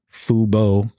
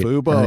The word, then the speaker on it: Fubo: Let's jump into FUBU. We missed covering FUBU's earnings. FUBO. Make sure I say that right. – FUBO. Earnings. Get that right, FUBO.